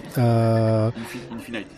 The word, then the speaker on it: Infinite: Bon, après euh, donc là, tu es euh, storyboarder pour euh, Marvel Infinity. Infinite. Infinite, pardon, c'est ma prononciation qui est pourrie.